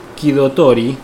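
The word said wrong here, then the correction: Kidotai (機動隊): ¿no? – Kidotai. – Kidotai. Ahí está.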